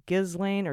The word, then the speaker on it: Ghislaine: I know that Ghislaine, I think your name is pronounced Ghislaine. – It's like a French name. – You were saying Ghislaine, but I think it's Ghislaine.